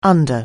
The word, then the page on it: under-: anglais